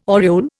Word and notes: aureole: Indian

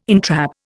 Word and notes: entrap: Indian

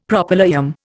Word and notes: Propylaeum: Indian

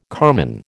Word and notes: carman: American English

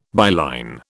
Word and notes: byline: British English

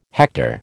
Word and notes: hector: American English

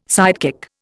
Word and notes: sidekick: Indian

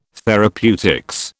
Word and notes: therapeutics: British English

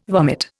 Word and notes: vomit: Indian

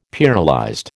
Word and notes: pearlized: American English

